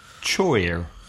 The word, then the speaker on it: choir: Choir, which is spelled C-H-O-I-R. So many Spanish people read it because in Spain you just read the letters and pretty much pronounce what you read, which looks like choir. – Yeah. – Pronunciation in English is choir. Choir. – My favorite one of all, you know how if a word ends I-T-E, it's nearly always pronounced at the end ite, for example, sight, S-I-T-E.